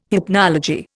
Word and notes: hypnology: Indian

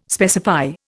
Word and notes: specify: Indian

